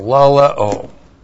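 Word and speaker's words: laleo (λαλέω): L-A-L-E long O, laleo.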